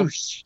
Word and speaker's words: Rush: Rush picking up a victory over Dalton Castle? – Roosh. It's Roosh. On Saturday it's Roosh.